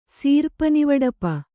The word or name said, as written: ಸೀರ್ಪನಿವಡಪ